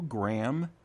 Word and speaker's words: Graeme: By the way, if you don't know, Graham is spelled G-R-A-E-M-E.